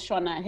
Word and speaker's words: Shona: Are you also China? – China. – Did I? That's Sh- Shona. Shona. Shona. Sorry, Shona. sorry. Shona. I, I, just went with the uh, English pronunciation.